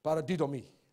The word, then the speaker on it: paradidomi: In the Greek word committed in 1 Peter chapter 2, 23, the Greek word is paradidomai. Did I say that right? Paradidomi. Paradidomi.